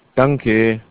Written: danke

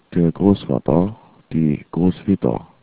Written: Großvater

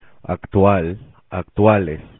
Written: actual

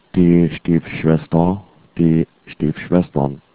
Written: Stiefschwester

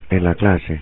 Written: en clase

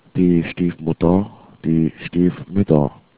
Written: Stiefmutter

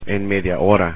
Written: en media hora

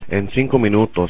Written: en 5 minutos

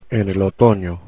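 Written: en otoño

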